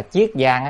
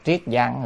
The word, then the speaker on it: Chiết Giang: Người ta nghe nói ở tỉnh Triết Giang hay là Chiết Giang á, có người đọc là Triết Giang, người đọc là Chiết Giang,